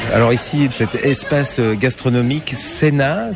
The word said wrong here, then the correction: Seina: Seina. – Ça s'écrit un peu comme la Seine, mais... – Voilà, avec un A à la fin, Seina, on prononce le I aussi.